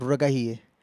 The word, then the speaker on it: Ruragahiye: It's Irving Ruragahie. I will spell it. Irving is IRVING. Ruragahie is a Kirundi name. It's spell it. It's RURAGAHIYE. So that's a Kirundi name. So Ruragahie.